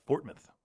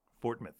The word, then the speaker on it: Fortmouth: No, that's not it. It's Fortmouth. Fortmouth. Clement Fortmouth.